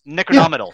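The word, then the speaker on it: Necronomidol: Not only this band, but the band you're talking about previously, that was, I'm probably going to mispronounce this, but Necronomicidal, right? – Necronomidal.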